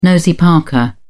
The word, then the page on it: nosy-parker: British English